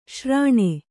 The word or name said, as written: ಶ್ರಾಣ